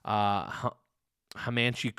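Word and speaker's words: Hamachi: I did also have my friend Diana try the Hamanchi Crudo. Yep, that's how you say it.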